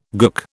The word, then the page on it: gook: British English